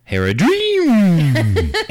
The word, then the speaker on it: Haradrim: Haradrim. – It's pronounced hair a dream.